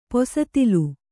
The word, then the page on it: ಪಿಸ್ತೂಲು: pistūlu